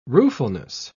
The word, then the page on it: ruefulness: British English